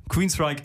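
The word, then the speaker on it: Queensrÿche: Denn Geoff Tate, Mitglied der Band, hat mal im Interview gesagt, wir verbrachten wirklich 11 Jahre damit zu erklären, wie man das ausspricht. Ja, Queensryche ist die richtige Aussprache und in dem Song,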